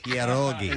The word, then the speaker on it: pierogi: Pierogi. – And that is the proper pronunciation.